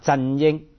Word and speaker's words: 振英: 嗯，因为振英的广东话发音就是啊，振英，就英文的 CY，振英哈这样子。